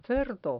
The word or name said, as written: cerdo